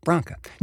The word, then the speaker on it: Branca: It's truly Branca, okay? Branca.